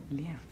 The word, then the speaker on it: Liev: If you're Russian, you say Liev. – Liev. – But the proper pronunciation of my family is Liev.